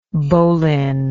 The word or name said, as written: bohlen